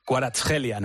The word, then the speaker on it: Kvaratskhelia: Sería Kvaratskhelia, ¿no? O sea, Mansilla, aplícate. – Otra nueva. – J, ya sabes que la KH allí es J.